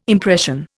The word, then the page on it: impression: Indian